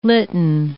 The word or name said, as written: litten